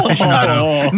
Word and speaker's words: aficionado: And I'm a big cheese aficionado. How do you say that word? – Aficionado. Not what you just said.